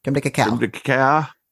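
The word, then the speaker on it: crème de cacao: Creme de cacao. – Creme de cacao.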